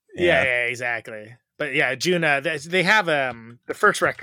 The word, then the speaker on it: Djunah: DJUNAH, Juna. – It's a silent D.